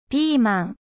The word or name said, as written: ぴーまん